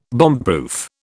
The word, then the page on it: bombproof: British English